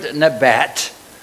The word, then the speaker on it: nabat: It's the word nabat. It would be spelled N-A-B-A-T.